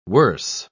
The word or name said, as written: worse